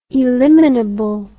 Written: eliminable

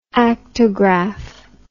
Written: actograph

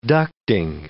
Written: ducting

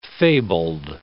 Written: fabled

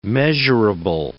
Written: measurable

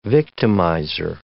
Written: victimizer

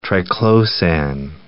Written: triclosan

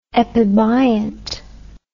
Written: epibiont

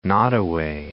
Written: Nottaway

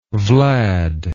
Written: vlad

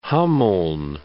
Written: Hameln

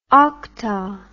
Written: okta

